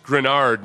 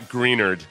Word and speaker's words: Greenard: Whoever wrote the script, he said, Texans fans, it's pronounced Grenard, not Greenard.